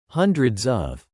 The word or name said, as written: hundreds of